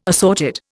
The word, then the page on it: assorted: Indian